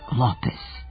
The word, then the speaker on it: Lopez: Mark Lopez. Dakle, akcenat je na prvom slogu. Ali zašto je Lopez, a ne Lopez? – Zato što smo španska imena više prenosili po pismu nego po izgovoru.